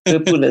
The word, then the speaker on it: Vipoola: Vipoola is correct.